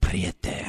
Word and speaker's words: Prieta: I said Agua Prieta, and you're like, Prieta.